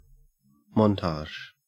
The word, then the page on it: montage: Australian English